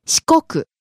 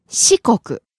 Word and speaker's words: 四国: 四国. There's another pronunciation, which is 四国. 四国. 四国. – Starting high and falling on the second syllable, staying low.